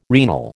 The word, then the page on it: renal: American English